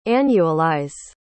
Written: annualize

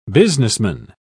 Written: businessman

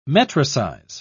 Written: metricize

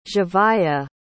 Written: zhavia

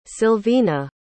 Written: Silvina

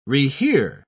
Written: rehear

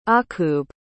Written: akub

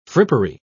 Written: frippery

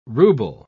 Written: ruble

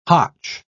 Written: hotch

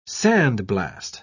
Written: sandblast

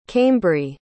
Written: caimbrie

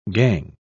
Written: gangue

